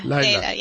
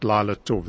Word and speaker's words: Layla: Layla, yeah. – Okay, not Lila Tov, it's Layla.